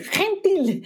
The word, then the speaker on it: gentil: Pero vamos poco a poco, con la ayuda de la profesora, que siempre ya gentil, tienes que decir gentil, eh, enfatizando, enfatizando la G,